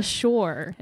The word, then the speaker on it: Shure: It's the Shure. Is that how you pronounce it? – Yes. – S-H-U-R-E.